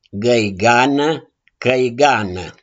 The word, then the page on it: GĂIGANĂ: CĂIGANĂ